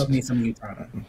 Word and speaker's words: Utada: It's spelled U-T-A-D-A, Yutada.